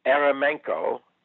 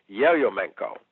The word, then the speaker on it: Yeryomenko: Today, if you look him up, you'll see more typically the Ukrainian spelling and pronunciation of his last name, which is Yeryomenko.